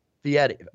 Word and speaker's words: Fieri: Fieri. F I E R. – Correct. – correct. Oh, yeah. – It, it, okay. it, doesn't look like it, but yeah, Guy Fieri.